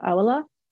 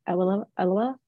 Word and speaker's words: Awelewa: Our guest today is Gbolabo Awelewa. I said that wrong. Awelewa.